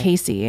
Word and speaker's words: Casey: Casey, in case, I don't know. Sometimes people are like, oh, it's Cassie.